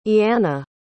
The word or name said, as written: ianna